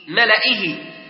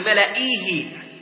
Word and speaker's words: ملئه: وكذلك وملئه، ملئه، هناك بعد الهمزة ياء تقرأ في غير المصحف يعني ملئيه، ملئيه، لكن نحن لا ننطق هذه الياء.